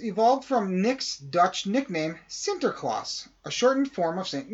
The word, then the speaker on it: Sinterklaas: Sinterklaas comes to New York. Now, you might think I said that wrong, but I didn't.